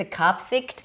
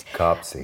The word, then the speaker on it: Kaapzicht: This is Kapsig, so it's spelled, for listeners, it's spelled K-A-A-P-Z-I-C-H-T, which I think you might say Kapsigt. – Kapsigt.